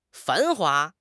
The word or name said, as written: 繁华